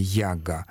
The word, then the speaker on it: яга: А первоначальное значение, согласно этому словарю, у слова «яга», кстати, они ставят ударение «яга», первоначальное значение «неприятная, злая».